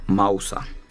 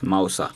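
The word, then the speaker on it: mousa (μοῦσα): Music basically, the origin of music itself, like the word and all those stuff, it's from the Greek language, and it's basically pronounced as mousa. Yes, mousa.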